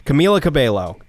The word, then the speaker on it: Camila Cabello: Camila Cabello.